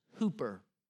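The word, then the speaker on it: hooper (ὑπέρ): Because if you look at the original Greek, it's the word intercession there, but what is added is an H-Y-P-E-R, pronounced hooper.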